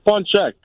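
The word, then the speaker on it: pączek: I was going to say with that punchki, it's punchek. – Yes. Thank you for the correct pronunciation. All right. – Yeah, it's punchki for plural and punchek for the single.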